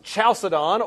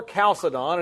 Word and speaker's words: Chalcedon: It's pronounced Chalcedon or Chalcedon, and its date is 451 A.D.